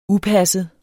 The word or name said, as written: upasset